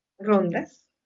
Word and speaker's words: rondas: Solamente 16 rondos. Rondos? – Rondas. – Rondas.